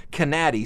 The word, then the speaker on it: Canady: The guy who played in the Ozark Mountain Daredevils named Steve Canady, and I've also heard it's pronounced Canady, C-A-N-A-D-Y.